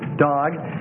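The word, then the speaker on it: dog (דָּג): So, D, long A, G, dog.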